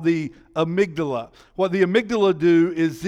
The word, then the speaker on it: amygdala: It's A-M-Y-G-D-A-L-A. A-M-Y-G-D-A-L-A. It looks like amygdala, but it's not. It's amygdala.